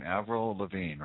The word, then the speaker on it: Avril Lavigne: Avril Lavigne, right? – Yes.